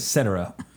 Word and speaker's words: Cetera: Cetera. Okay, I was about to say Cetera.